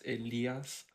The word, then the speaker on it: Elias: So, for example, I don't want to correct you, but you said my name wrong. It's Elias, like the Spanish way.